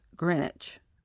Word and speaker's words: Greenwich: The English were the first to draw the prime meridian on maps, so the line runs through the city of Greenwich, pronounced Greenwich, in England, and through Western Africa and Antarctica.